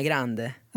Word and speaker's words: Grande: Uttalar hon det själv? – Jag skulle nog säga, för hon är ju typ halv eller kvarts italienska. – Ja. – Jag skulle nog säga Ariana Grande.